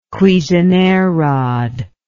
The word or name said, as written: cuisenaire rod